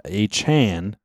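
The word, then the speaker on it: Achane: It's not A Chain which is much cooler. We all agree it's very much much cooler. He wants it pronounced a Chan. – Chan, like a Jackie Chan.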